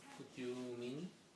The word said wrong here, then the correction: mind: Tiene una I. Tú lo estás pronunciando de una manera, se pronuncia de la otra manera. Una I latina, ¿no? – Would you mind? – Yeah.